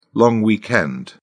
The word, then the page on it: long weekend: British English